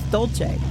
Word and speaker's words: Dolce: I always thought it was Joe Dulce. – No, it's Dolce.